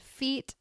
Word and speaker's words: Feat: It's by the brand Feet. Yes, that is spelled F-E-A-T.